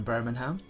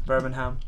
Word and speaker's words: Birmingham: That's correct. You're pronouncing the G. Birmingham. – You're not meant to.